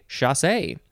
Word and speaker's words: chassé: Next we have chasse, C-H-A-S-S-E, with an accent on the E. So you have to say chasse.